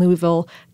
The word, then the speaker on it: Louisville: Well, the Kentucky Derby is a spectacle horse race, spectacular, conducted every year at the Churchill Downs Racetrack in Louisville, Kentucky. And I'm from Ohio and South Florida, so I can't say Louisville quite correctly. There's like an extra syllable of vowel in there.